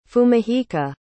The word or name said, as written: fumihiko